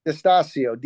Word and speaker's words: Distasio: Distacio. D-I-S-T-A-S-I-O.